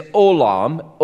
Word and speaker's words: olam: Now, that phrase, from everlasting, the Hebrew term there is olam, O-L-A-M, short A, long O. Olam, and it means eternity.